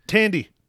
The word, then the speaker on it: Thandie: Uh, it is starring uh, Chris Pine, uh, Tawandi Newton. – Thandie. Thandie Newton. That's how you ah, pronounce it. Thandie.